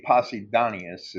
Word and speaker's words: Posidonius: It should be Posidonius is the correct way to say that.